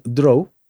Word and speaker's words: draw: Allí pronunciamos draw, dibujar, con la letra L al final.